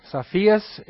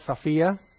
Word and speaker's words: Sophia: As Pastor Bob talked about in the Greek class, we often pronounce this Sophia because we know people that have that name, Sophia, but it's actually Sophia. But I'm not going to have a problem if it's Sophia.